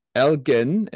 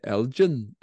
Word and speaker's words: Elgin: Elgin, it's a hard G. I did read that much in my research. Some people apparently call them the Elgin and try and turn it into gin and tonic.